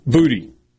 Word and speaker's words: Boutte: Well, somewhere along the way, Kayshawn Boutte let the people in the LSU media relations office know that his name had been mispronounced. And it's actually Booty.